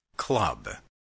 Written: club

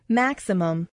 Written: maximum

